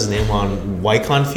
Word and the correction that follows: Joaquin: Phoenix. Joaquin. – Joaquin.